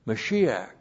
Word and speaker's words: Mashiach: Mashiach.